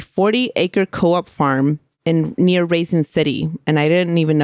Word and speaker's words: Raisin: Yeah, like raisin. – Like raisin, like raisins. A raisin. – Yeah. – The ones you eat.